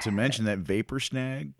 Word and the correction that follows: Vapor Snag: Vapor Snag, yeah.